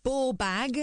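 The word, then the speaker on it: bawbag: Bobag, pronounced ball bag, yes.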